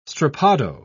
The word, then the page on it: strappado: British English